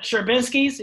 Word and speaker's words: Sherbinsky's: and then just other pop-ups with Sherbinsky's, is that how you say it? – Yep, Sherbinsky's. Yeah, man, tell me about how that came about and like what that business is all about.